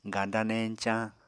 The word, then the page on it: ganda-neñcañ: apatani